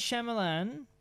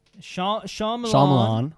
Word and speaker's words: Shyamalan: Now, M. Night Shyamalan, Shyamalan, that's how you pronounce it, was offered to direct the Harry Potter movie countless times.